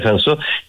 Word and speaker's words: Cinsault: Por ejemplo, hay cepas como el Cinsault, que se llama, se escribe Cinsault, o se pronuncia Cinsault,